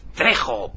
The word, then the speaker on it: Trejo: ¿Dani Treja dices? – Trejo. – ¿Trejo o Eva Méndez? ¿A quién conocéis? – Hombre, está más buena Eva Méndez, bastante más. – Sí. ¿Quién es Dani Treja? – Trejo.